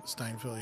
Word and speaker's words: Steinfeld: Steinfeld, yeah, my celebrity crush.